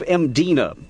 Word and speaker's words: Mdina: She wanted to take me to the classic city of Mdina. There's no vowel in that first syllable. It's just M-D-I-N-A.